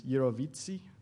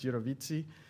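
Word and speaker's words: jirovecii: Now it's pneumocystis gyrovitzii. That's how it's actually pronounced. I looked it up. Everybody says gyrovitzii or something like that. It's gyrovitzii. So PCP obviously was something that wasn't really discussed very much until the 1980s.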